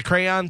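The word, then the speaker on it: Craiyon: The crayon thing? Okay. If you can put it up there. So it's a thing on the internet. It's an open source artificial intelligence called Crayon. But it's spelled CRAION, which will draw pretty much anything you want in about 2 minutes.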